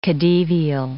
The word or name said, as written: khedivial